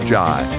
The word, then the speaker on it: Zhai: Z-H-A-I, it's pronounced Jai. Cynthia Jai. And that is spelled Cynthia with a C, Z-H-A-I, Z-Zebra, H-A-I.